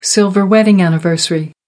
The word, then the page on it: silver wedding anniversary: American English